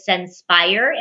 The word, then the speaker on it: Censpire: And my company name is Senspire, and it's spelled C-E-N-S-P-I-R-E. And what it is, it's all about getting centered and inspired. So I joined the two words together.